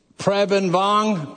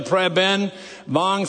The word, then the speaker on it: Preben Vong: Preben Vong, prayer like President Ben, like Benjamin, put them together, Preben Vong. Think in Texas ways, it would be Vang.